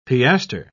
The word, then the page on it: piastre: British English